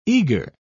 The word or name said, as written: eager